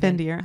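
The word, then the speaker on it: Fendyr: Her last name, and you're gonna make fun of me, I'm probably gonna say it wrong, but Findir. Is that how they say it? – Findir. – Huh? Okay. – It's like the Findir wolf? – Yes.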